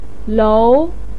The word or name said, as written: lou2